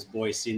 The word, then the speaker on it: Boise: If I remember right, I had to teach you that it was Boise, not Boise. – Yep. That is somewhat true. – Yeah. Well, I grew up much closer to Boise than you did.